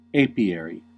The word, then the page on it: apiary: American English